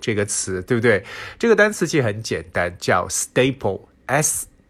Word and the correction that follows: stable: 啊、 uh,，excuse me，啊、uh,，发音有点问题。 Stable，alright，S T A B L E，stable，刚才读成了这个订书机的词了。 Stable，那本身 stable 作为形容词来讲呢，是稳定的一层意思。